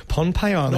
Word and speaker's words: Pohnpei: Pohnpei Island. Not Micro- Pompeii. Not Pompeii. Pon. – No, Pon. with an N. – And the H is silent.